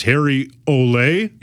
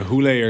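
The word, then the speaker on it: Hole: Apparently, it might be supposed to be pronounced Harry Ole. – Yeah, Hule or something. Hule, something like that.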